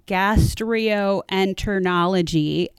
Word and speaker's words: gastroenterology: However, and this is not a joke, the head of gastroenterology at Children's Hospital of LA, whose name is Dr. Frank Sinatra.